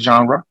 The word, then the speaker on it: genre: That's right. I said genre. It's not spelled the way it's pronounced,